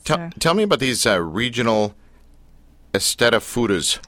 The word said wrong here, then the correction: Eisteddfod: So it's pronounced Eisteddfod.